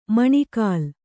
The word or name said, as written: ಮೊಣಕಾಲ್